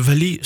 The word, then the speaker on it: vali: C'est quoi le valiya ? – Alors le vali, ça se prononce vali.